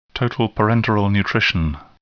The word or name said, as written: total parenteral nutrition